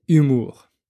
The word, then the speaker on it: humour: Connaissez-vous la différence entre les mots humeur et humour Enfin, déjà, est-ce que vous entendez cette différence Humeur, humour. Humeur, ça s'écrit H-U-M-E-U-R et ça signifie l'état d'esprit dans lequel euh, vous êtes à un moment donné.